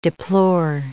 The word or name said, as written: deplore